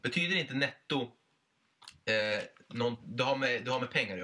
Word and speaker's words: Netto: Du uttalar netto tysk. Netto.